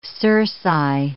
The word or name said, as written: cerci